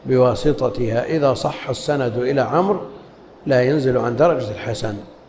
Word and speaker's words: عمرو: قال وعن عمرو بن شعيب، بعض طلاب العلم ينطقون عمرو بالواو والضم ومدري ايش، هذا الكلام مو صحيح هنا. وعن عمرو بن، والواو هذه يؤتى بها للتفريق بين عمرو وعمر، وإلا تأتي عليه الحركات كلها. اسمعوا، يتحدث عن شخص عمرو، ما بصحيح، ما بعمرو وعمر. رأيت عمرا، مررت بعمر، فالواو إنما لا تنطق، إنما يؤتى بها للتفريق بينه وبين عمر.